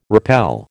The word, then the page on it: rappel: American English